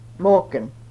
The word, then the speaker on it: Maagen: M-A-A-G-E-N, Morgan.